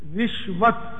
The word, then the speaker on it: رشوت: فتح الباری جلد پنجم صفحہ 539 پر اس کے تین تلفظ بیان ہوئے ہیں. رشوت، رشوت اور رشوت، تینوں درست. اردو میں عام طور پہ رشوت بولتے ہیں اور گجراتی میں رشوت بولتے ہیں.